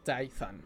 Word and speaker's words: Tython: Eh, en el planeta Tithon, que Tython, Tython, así se pronuncia, Tython.